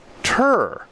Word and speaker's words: tour: But meanwhile, in other parts of the country, they say tur. And that rhymes with fur, as in one, two, three, fur. which is how they say it in Utah, or at least did when I was there.